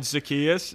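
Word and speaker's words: Zaccheaus: So if you go for, like, catches, so Mike Davis had 10 more catches than, I'm going to pronounce his name wrong, but Zacchaeus. – Olamide Zacchaeus, is yes. – that it? – Yes, yes. So he had 10 more catches.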